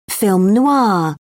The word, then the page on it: film noir: British English